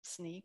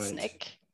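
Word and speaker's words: Snyk: The name of the company is actually Sneak, which is a little tricky, pronounced Sneak or right. Snick, depending on who you're talking to in the organization.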